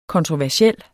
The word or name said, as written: kontroversiel